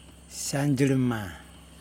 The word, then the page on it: sandJilima: Yokoim